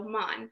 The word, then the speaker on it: Mahn: So my first four letters of my name is M-A-H-N, so Mon. And naturally, it just rhymes with the John.